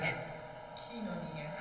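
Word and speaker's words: Kinonia (κοινωνία): Kinonia. – Oh, kinonia. Right, I'm going to have to change forever now. Kinonia.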